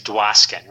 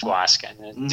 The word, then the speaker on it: Dwoskin: When I was growing up, so my name's Dwoskin, right? It's just Dwoskin. D W O S K I N.